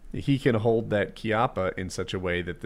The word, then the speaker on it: Chiappa: Is that how you pronounce Chiappa? – Yeah. I took Italian. – Yeah. Okay, then. Chiappa.